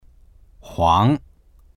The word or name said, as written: huang2